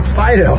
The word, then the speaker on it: Phideaux: her name is spelled P-H-I-D-E-A-U-X. – Like a French Fido, yeah.